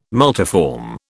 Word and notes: multiform: British English

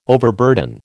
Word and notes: overburden: American English

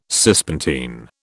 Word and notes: Cispontine: British English

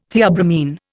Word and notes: theobromine: Indian

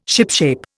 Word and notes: shipshape: Indian

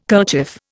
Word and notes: kerchief: Indian

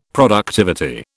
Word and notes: productivity: British English

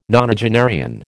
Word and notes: nonagenarian: American English

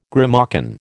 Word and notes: grimalkin: American English

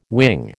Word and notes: wing: American English

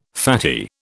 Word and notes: fatty: British English

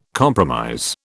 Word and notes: compromise: British English

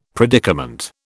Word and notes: predicament: British English

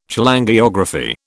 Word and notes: Cholangiography: British English

